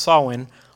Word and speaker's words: Samhain: Over time, Samhain, and I know it's that word up there is pronounced Samhain.